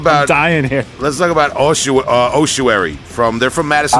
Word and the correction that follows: Ossuary: Uh, I, I pronounced it wrong. Long Island, Ossuary from Madison, Wisconsin, right?